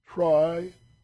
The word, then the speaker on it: triiodothyronine: And the other one is a little tougher, triiodothyronine.